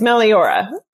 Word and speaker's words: Meliora: Well, I'm also going to look up, how did I say it? Meliora? – Yeah, that's very common. It's Meliora.